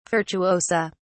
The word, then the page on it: virtuosa: British English